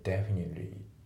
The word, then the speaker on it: definitely: であと発音なんだけど Definitely のその T の部分がねあまりちょっと発音されてないですよね。 Definitely Definitely じゃなくて Definitely ですね。